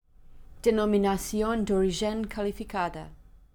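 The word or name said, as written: Denominacion De Origen Calificada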